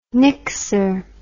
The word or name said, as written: nixer